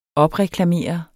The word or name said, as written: OPREKLAMERER